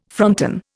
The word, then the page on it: fronton: Indian